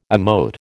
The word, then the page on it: emote: American English